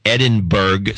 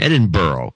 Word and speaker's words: Edinburgh: And it's always pronounced Edinburgh. – Okay. – But it should be pronounced Edinburgh.